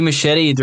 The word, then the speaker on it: Muschietti: Andy Muschietti, director of It 1 and 2.